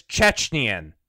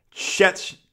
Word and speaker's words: Chechen: The word is Chechen. – Chechen. – Bingo.